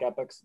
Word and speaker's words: Epoch: Well, it's not spelled like Epics, it's spelled like Epoch, but Epics.